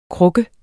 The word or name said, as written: kruk